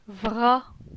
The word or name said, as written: Vra